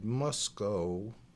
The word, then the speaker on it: Musco: I said Musco. It's like must go.